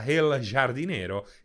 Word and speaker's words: El jardinero: Uh, si pronuncia con la J tipo H aspirata, El jardinero, El jardinero, El Hardinero, ok, uh, è un po' difficile per me fare la H aspirata.